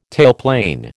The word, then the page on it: tailplane: American English